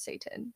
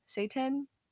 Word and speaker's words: seitan: I think it's pronounced Satan. Satan. – Is this, what is this? – It's cream? Like, no, it's a meat substitute. It's made out of wheat gluten. – I've never heard of it. – It's spelled S-E-I-T-A-N, Satan.